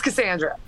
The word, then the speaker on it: Casandra: And hey, are we saying your name right when we say, is it Casandra or Cassandra? – It's Casandra.